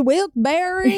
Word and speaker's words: Wilkes-Barre: Well, I thought it was Wilkes. What did I say? Bear. – Yeah. – And then we looked up this like local newscast and is this guy, he's like, I said it wrong. – Turns out it's actually Wilkes-Barre, Pennsylvania. Yep. I've been saying it wrong my whole life.